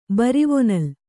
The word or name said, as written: ಬರಿವೊನಲ್